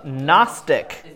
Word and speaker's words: Gnostic: It's a silent G, just like gnat. So it is the Gnostic.